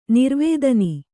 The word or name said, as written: ನಿರ್ವೇಧನ